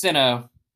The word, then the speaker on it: Sinnoh: He's gonna get an evolution with a Shino stone or Shino. Shino? I like with Shino. – Shino stone. – I think it's Sinnoh. – How do you say it?